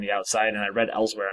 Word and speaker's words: Elsweyr: Is that how it's pronounced? – Yeah, I'm pretty sure. – Elsweyr.